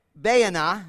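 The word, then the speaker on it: Baanah: The name of the one was Baana, is how you say it,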